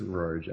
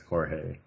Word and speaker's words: Rorge: I think it's Rorge. I don't think it's Jorge.